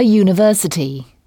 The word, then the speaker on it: university: Là encore, on emploie e et non an, car le son qui suit est celui d'une consonne, un U qui se prononce U, donc A University.